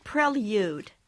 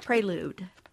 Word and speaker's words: prelude: So we started hearing things like, you know, capsule, prelude instead of prelude.